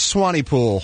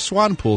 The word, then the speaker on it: Swanepoel: I always thought it was Swanepoel until I looked up pronunciation today, and it is Swanepoel.